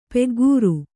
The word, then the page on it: ಪೆಗ್ಗೂರು: peggūru